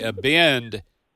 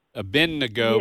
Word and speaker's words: Abednego: It's, people yes. say Abend. Abednego, yes. but it's a bed, yep. A-B-E-D, oh. Abednego.